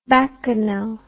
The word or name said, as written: bacchanale